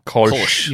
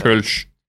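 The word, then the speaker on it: Kölsch: Kolsch. – You were teaching us about that. – Kolsch. – Very German. – Kolsch.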